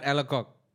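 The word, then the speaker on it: Alacoque: I do not think there's any other way to pronounce it but Alakok.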